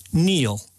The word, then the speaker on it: kneel: Finally, raspberry, answer, Neil. – How are you spelling Neil? – K-N-E-E-L, Neil.